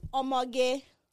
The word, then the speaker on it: Omoge: It's pronounced Omoge, which means in Yoruba, it means beautiful young lady.